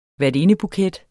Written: værtindebuket

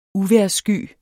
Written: uvejrssky

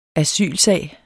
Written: asylsag

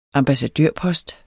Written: ambassadørpost

